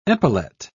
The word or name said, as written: epaulet